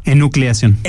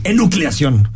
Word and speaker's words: Enucleación: Hoy hablamos de temas que te interesan, así a ver si lo pronuncio bien, un el Enulciación Enucleación. Enucleación.